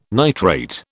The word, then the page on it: nitrate: British English